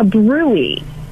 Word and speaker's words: bruit: You want the doctor to check, put their stethoscope on your belly and check for what's called a BRUI. BRUI is spelled brute. Now you'll never forget it. BRUIT.